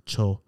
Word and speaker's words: Xolo: A cholo dog, spelled X-O-L-O, right? So right. Like Mexican pronunciation of the X of cholo.